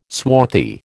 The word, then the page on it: swarthy: American English